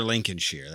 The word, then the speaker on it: Lincolnshire: But you're Lincolnshire. That's how it's pronounced.